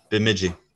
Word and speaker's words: Bemidji: And it was that Cole Caufield's Wisconsin Badgers went out to, and I'm not sure how to pronounce this, Bemidji State. – Bemidji.